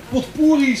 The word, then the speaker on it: potpourri: Nou, en ze hebben een potpourri samengesteld, ook haar eigen woorden. Spreek je dat verkeerd uit, vriend? Ja, zo zei Karin het, hoor. Hoe moet ik dat uitspreken? Potpourri.